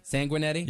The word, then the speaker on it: Sanguinetti: We have a former Carolina Hurricane, Bobby Sanguinetti. Did I say that correctly? – Sanguinetti? Yep.